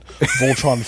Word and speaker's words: Voltron: You were always a bigger Voltran, Voltran, Voltron fan. – Obviously, kid can't even pronounce it correctly.